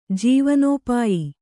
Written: ಜೀವನೋಪಾಯ

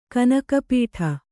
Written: ಕಣ್ಣುಕಪ್ಪಡಿ

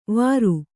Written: ವಾರು

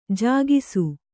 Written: ಜಾಗಿಸು

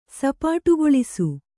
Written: ಸಪಾಟುಗೊಳಿಸು

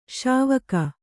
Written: ಶಿವಕ

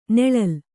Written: ನೊಳಲೆ

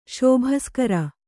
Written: ಶಾಬಾಸ್‍ಗಿರಿ

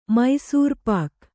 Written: ಮೈಸೂರ್‍ಪಾಕ್

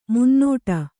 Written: ಮುನ್ನಡೆ

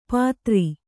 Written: ಪಾದರಿ

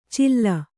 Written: ಚಿಲ್ಲ

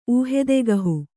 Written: ಊಹೆದೆಗಹು

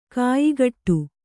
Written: ಖಯಖೋಡಿ

